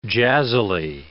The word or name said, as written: jazzily